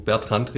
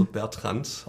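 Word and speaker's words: Bertrandt: Genau, ich bin schon mal ganz froh, Martin, dass du Bertrand richtig ausgesprochen hast. – Also oftmals. was sagen sonst die Menschen? – Ja, oftmals denken, also wird gedacht, wir sind ein französisches Unternehmen. – Also Bertrand, ist sehr gern ausgesprochen, aber wir sind ganz klar in den ein Unternehmen mit schwäbischen Wurzeln, also Bertrand, auch richtig ausgesprochen.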